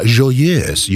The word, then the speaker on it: Joyeuse: Nu, det här är alltså fjärde satsen, La Joyeuse. – Ja, Joyeuse, just det.